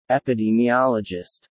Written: epidemiologist

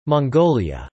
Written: Mongolia

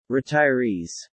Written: retirees

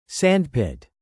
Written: sandpit

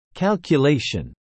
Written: calculation

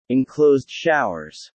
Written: Enclosed showers

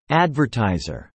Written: advertiser

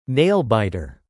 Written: nail-biter

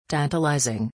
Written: tantalizing

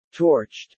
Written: torched

